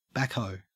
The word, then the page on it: backhoe: Australian English